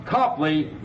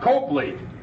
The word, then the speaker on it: Copley: All right, folks, we're ready to leave Copley Square, pronounced Copley, not Copley. After all, you wouldn't call a cop a cope, would you? So we say Copley Square.